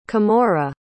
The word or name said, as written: kimora